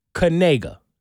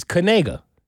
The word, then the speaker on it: Knigga: Turns out, K-N-I-G-G-A actually is pronounced Kanega. – Okay. – So their family name is Kanega. It's spelled K-N-I-G-G-A, but it's Kanega. – A-E-I-O-U, right? – Those are the vowels.